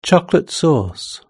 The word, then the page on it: chocolate sauce: British English